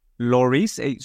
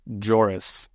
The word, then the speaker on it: Lloris: How are they saying it on the broadcast? – Joris. – Are you serious? – Yeah, he was pronouncing it with a J.